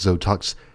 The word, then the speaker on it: Xotox: I guess it's Zotox. It's spelled X-O-T-O-X. It's also a palindrome.